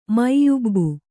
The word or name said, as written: ಮೈಯುಬ್ಬು